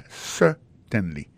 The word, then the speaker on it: certainly: Alors, en parlant de accent, gardez bien pour voir dans mot certainly, accent tombé sur premier syllabe là. Yes, certainly.